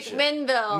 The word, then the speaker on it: McMinnville: So, McKenneville, all that McMinnville. – Shit. McMinnville.